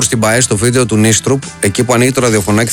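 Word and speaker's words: Nystrup: Θέλω να πω ότι ευτυχώς που είδαμε αυτό το βίντεο, γιατί ακούσαμε τον Δανό κύριο να προφέρει το όνομα του Νίστρουπ, το οποίο είναι Νίστρουπ, που έχονται δύο Ι.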